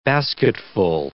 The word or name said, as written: basketful